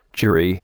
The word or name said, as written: jury